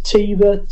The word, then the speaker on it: Tiiva: This is a single by, I think you pronounce this Tiva, T-I-I-V-A.